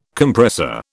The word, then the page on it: Compressor: British English